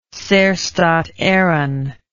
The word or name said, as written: Saorstat Eireann